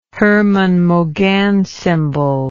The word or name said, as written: Hermann-Mauguin symbol